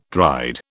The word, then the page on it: dried: British English